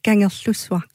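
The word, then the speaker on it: Kangerlussuaq: Ganger Slusvar. – Ganger Slusvar, det er, ja.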